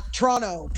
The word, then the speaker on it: Toronto: See, that's how I know you're not from Toronto, because you're pronouncing the last T. – Yes, I know. – Yeah, it's Toronto.